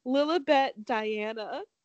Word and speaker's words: Lilibet: And she's named, is it Lilibet? Is that like, how they say it? – Yeah. – Lilibet Diana.